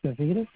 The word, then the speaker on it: Savides: Yes, my name is Nick Savitas, and I'll spell it. S-A-V-I-D-E-S.